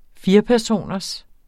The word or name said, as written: firepersoners